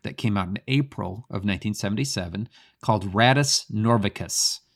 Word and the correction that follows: Rattus Norvegicus: Hey gang, I just wanted to drop a quick note here to let you know that I butchered the name of this album when I first recorded this podcast. The correct pronunciation is Radius Norvegicus.